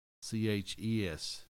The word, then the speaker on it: Natchitoches: That's the first thing I thought of when we hit Natchitoches because it's spelled not anything like it sounds. – Right. – Natchitoches. It's pronounced Natchitoches.